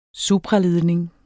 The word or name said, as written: supraledning